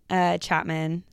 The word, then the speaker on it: Chapman: My name is Reagan Chapman, spelt with an H.